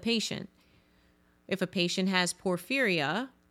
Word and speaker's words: porphyria: I remember in school I would always pronounce it porphyria, but that's not it. It's a porphyria.